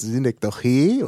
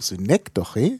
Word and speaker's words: Synekdoche: Entweder griechisch, dann heißt es Synekdoche, weil es in altgriechisch hinten betont ist. Dann ist es aber ins Deutsche gekommen über das Lateinische und da ist die Betonung Synekdoche. Und dann gibt es noch die deutsche Betonung, vorletzte Silbe bei Fremdwörtern, Synekdoche.